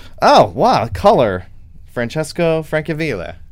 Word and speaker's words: Francesco Francavilla: Francesco Francavilla.